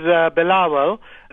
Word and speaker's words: Bilawal: Am I pronouncing his first name correctly? – No, his name is Bilawal.